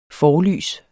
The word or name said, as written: forlys